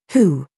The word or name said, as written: HAHA